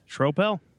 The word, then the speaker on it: Schroeppel: I pronounced it right? – Schroeppel. – Okay. Schroeppel. Okay. – It's German. – It looks Schroeppel. It looks for sure Schroeppel, but Schroeppel.